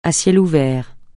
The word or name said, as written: à ciel ouvert